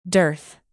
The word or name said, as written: dearth